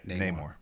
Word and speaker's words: Namor: Namor? – Yeah, Namor.